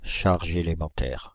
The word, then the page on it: charge élémentaire: France (Île-de-France); /ʃaʁ.ʒ‿e.le.mɑ̃.tɛʁ/